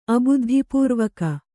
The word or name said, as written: ಅಬುದ್ಧಿಪೂರ್ವಕ